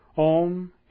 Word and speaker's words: Om: It is also called Om, which is the basis of all sound. Om, and yet is pronounced as though it were spelled O-M.